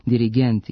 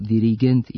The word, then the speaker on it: dirigent: Zbog toga se oblici sa kratkosilaznim akcentima na posljednjem slogu, dirigent i asistent, akcentuju kao dirigent i asistent sa kratkouzlaznim akcentima na pretposlednjem slogu.